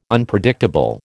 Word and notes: Unpredictable: American English

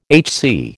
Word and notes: HC: American English

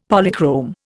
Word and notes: Polychrome: Indian

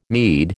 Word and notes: meed: American English

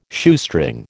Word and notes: Shoestring: American English

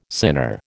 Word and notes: sinner: American English

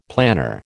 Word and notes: planner: American English

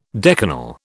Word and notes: decanal: British English

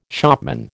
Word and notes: shopman: American English

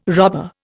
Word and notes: robber: Indian

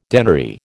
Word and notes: denary: American English

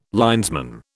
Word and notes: linesman: British English

